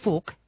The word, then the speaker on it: Phuc: I mean, there's a very popular Vietnamese name pronounced Phuc, P-H-U-C.